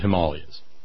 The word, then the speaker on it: Himalayas: But he would come back and run for governor of Alabama, and he had flown the hump over the Himalayas. And see how I said it right? – Yes, sir, you got it.